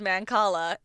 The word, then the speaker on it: Mancala: So we have Sophie G, who bought us 3 beers and said, "It's Mancala, pronounced Mancala.